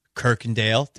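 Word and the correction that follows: Kuykendahl: Did I get it right? I did. Good boy. Good boy. Right? I did get it right, right? – Kirkendall. – Damn it. Damn it.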